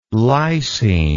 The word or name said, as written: lycine